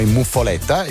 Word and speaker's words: muffoletta: che in realtà la pronuncia esatta, almeno in quel di Palermo, è muffoletta.